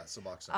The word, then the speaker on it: Suboxone: Suboxone on them. – Yeah. Am I saying that right? – Yeah, Suboxone.